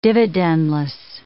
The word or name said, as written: dividendless